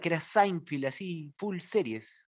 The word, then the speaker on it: Seinfeld: No, Seinfeld. – Seinfeld. – Sí, chequenlo. Búscalo ahorita mismo y vas a ver, no hay ninguna I en la segunda sílaba.